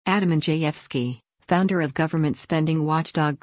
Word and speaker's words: Andrzejewski: Andrzejewski, pronounced Andrzejewski, came to prominence in Illinois during the Tea Party movement and soon launched a short campaign for governor in 2010.